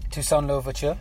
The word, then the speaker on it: Toussaint Louverture: Toussaint Louverture. – Yeah, Toussaint Louverture.